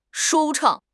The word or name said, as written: 舒畅